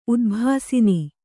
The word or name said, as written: ಉದ್ಭಾಸಿನಿ